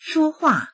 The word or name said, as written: shuo1hua4